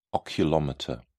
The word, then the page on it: oculomotor: British English